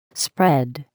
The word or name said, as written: spread